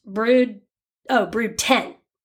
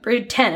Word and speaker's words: Brood X: Brood X, pronounced Brood 10. Sorry, mm-hmm. Brood 10. It was a Roman numeral.